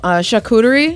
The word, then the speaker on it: charcuterie: Oh, charcuterie? – Yeah.